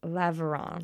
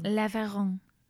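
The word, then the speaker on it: Laveran: Alphonse Laveron. – Laveron. – Thank you. It was beautiful. – De rien. – What? That means you're welcome. – Clearly, I know zero French. Okay, so this guy, Laveron, glanced into a microscope at a smear of blood from a malaria patient and saw something wriggling in the blood cells.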